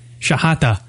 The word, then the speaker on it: Shehata: S-H-E-H-A-T-A. Shahata.